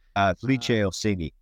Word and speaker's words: Felice Orsini: I hope I'm pronouncing the name correctly, Felice Orsini. – Felice Orsini, yeah.